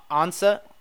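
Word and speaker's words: Ansah: what is it, Ansa? – Yep. – Ziggy Ansa.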